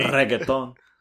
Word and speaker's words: reggaeton: You got to roll your R as a Delarosa. – Oh, sorry. – Reggaeton.